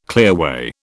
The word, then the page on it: clearway: British English